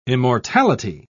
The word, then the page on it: immortality: British English